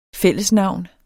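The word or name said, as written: FÆLLESNAVNENES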